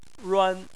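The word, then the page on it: ruan: tone 1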